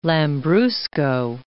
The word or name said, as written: Lambrusco